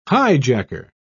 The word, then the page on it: highjacker: British English